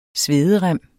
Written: SVEDEREMMEN

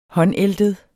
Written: håndæltet

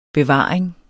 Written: bevaring

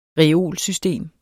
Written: reolsystem

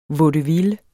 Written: VAUDEVILLES

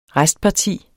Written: restparti

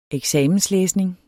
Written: eksamenslæsning

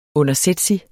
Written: undersætsig

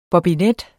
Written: bobinet

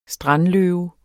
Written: strandløve

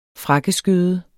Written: frakkeskøde

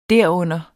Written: derunder

